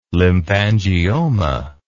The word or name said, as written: lymphangioma